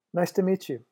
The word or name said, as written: nice to meet you